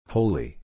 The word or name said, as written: wholly